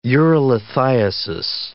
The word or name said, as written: urolithiasis